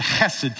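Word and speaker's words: chesed (חסד): It's that word, you gotta kind of do that little fluttery thing in your throat to say it right. It's the word chesed.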